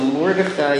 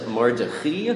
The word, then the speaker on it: Mordechai: And this issue comes from a Mordechai in Simin Tafresh Ayin Tes. By the way, for Sfaradim apparently pronounce it Mordechi, and Ashkenazim pronounce it Mordechai.